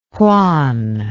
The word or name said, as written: hwan